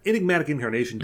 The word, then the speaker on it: Enigmatic Incarnation: It's in the Incarnatic Enigmation. Why do I always mispronounce that card? Enigmatic Incarnation deck.